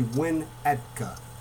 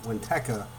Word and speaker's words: Winnetka: And I kept saying Windhecka, which I guess is wrong. Thanks for pointing that out. I had a couple people send messages saying I said it wrong. It's actually Winnetka not Winteka, Winetka.